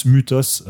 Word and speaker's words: mythos: Normalement, ça se prononce mutos, euh, comme par exemple lubris, le y en fait en grec c'est un u. Mais bref, pour le, la clarté, je vais dire mythos parce que de toute façon, c'est devenu ensuite mythologie, mythe, etc.